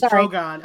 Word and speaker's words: Drogon: It's Drogon.